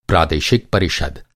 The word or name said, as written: प्रादेशिक